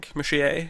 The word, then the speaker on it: Muschietti: Muschietti.